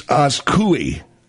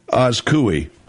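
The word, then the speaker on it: Azcuy: It's Askui. Askui. – Gesundheit. – You're welcome. Get out of here. Anyway, this woman, Askui, she took on the billionaire after being fired this week.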